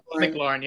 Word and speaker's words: McLaurin: McLaurin, yeah.